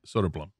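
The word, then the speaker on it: Söderblom: Blum. It's like Blum. You say quickly. It says Soder is the first part. And it's Soderblom. – What do the two dots over the O mean? – The umlauts? – Yeah. – Long O, I believe is what that means. It sounds like the letter is O. Blum. – So it's Soderblom.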